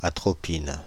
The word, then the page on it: atropine: France (Île-de-France); /a.tʁɔ.pin/